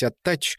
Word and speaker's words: Аттач: Сын говорил, что кличка означает «прилипший», типа как репейник, и что надо произносить «Аттач» с четким разделением двух букв «Т» между слогами и с ударением на вторую «А».